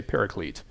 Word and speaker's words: paraclete: It's a transliteration of, let me get it right, parakletos. That's the Greek word, parakletos. And so other cultures, English, for example, has just transliterated it. We still kind of pronounce it like that, but we make it more like our language, so we just say paraclete.